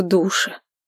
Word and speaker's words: душе: А еще меня жутко тошнит в душе. Ударение в этом слове на «у», а можно и на «е».